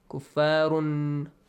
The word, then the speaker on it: كفار: do not pronounce it completely yes Run, when you pronounce the noon sound, the tongue touches the gum area at the top. – Yeah. – Kuffarun. Do not let it touch there.